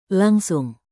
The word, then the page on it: langsung: Indonesian